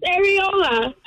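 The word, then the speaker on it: Arreola: Seriously, it's Areola.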